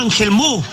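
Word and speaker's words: Angelmó: Y resulta que la gente de allá, para donde vaya, voy para Ángel Mo. No alcanzan a pronunciar la T final, por eso esa parte se llama Ángel Mo, de Ángel Montt.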